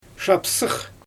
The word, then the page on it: ʃapsɯɯx: Xyzyl